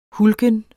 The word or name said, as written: hulken